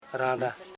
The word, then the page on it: randa: ran.da; Koro